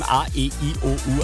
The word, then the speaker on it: AEIOU: Aussprechen kann ich sie nicht, wird geschrieben A-E-I-O-U, also wie die Vokale. A-E-I-O-U. Okay, ich kann sie doch aussprechen. A-E-I-O-U, Oliver Heldens, um kurz nach halb.